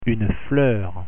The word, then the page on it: fleur: French